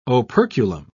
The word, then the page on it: operculum: British English